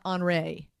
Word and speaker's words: Henri: See, I was pronouncing it Henry, but I was told it's called Henri. It's H-E-N-R-I.